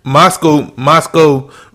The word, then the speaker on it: Moscow: We got this Costco Mule. Moscow Mule. – Moscow Mule. – You have to put the cowl on it. – That's how you pronounce it, Moscow.